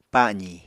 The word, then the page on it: pa7zi: Apatani